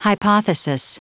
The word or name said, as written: hypothesis